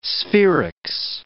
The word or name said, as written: sferics